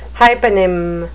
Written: hyponym

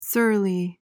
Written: surly

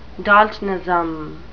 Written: daltonism

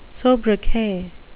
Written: sobriquet

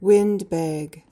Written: windbag

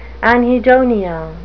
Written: anhedonia

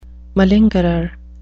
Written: malingerer

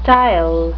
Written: stile

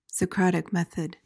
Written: socratic method

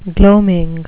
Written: gloaming

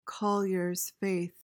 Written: collier's faith

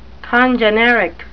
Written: congeneric

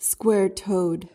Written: square-toed

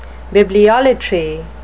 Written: bibliolater

